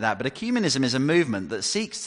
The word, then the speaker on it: ecumenism: Some people say ecumenism, some people say ecumenism, but what should we call it? Give us the authoritative pronunciation. We're going to call it ecumenism. We're going to go for that.